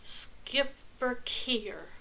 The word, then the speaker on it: Schipperke: The name is pronounced Schipperke.